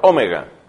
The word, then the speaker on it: Omega: Yo soy el Alfa y la Omega. Dijimos que se pronunciaba Omega.